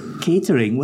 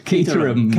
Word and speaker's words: Caterham: Catering, wasn't that Tony Fernandez's team? – I never knew how to pronounce that. – Caterham.